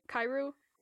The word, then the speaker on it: Kyrou: Kyrou.